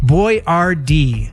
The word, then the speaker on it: Boyardee: His American customers and salesmen struggled with the pronunciation of his last name. So the chef decided to change it to the phonetic Boyardee.